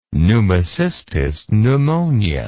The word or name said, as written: pneumocystis pneumonia